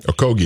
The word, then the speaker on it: Okogie: With Covington, Saric, Kyogi, I'm sorry, I probably said that wrong. – Okogie.